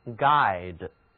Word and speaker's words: guide: G-U-I-D 首先学习领导、指导，就是动词 guide，字母 G-U 组合起来读作 g，而字母 E 是一个动词后缀 guide。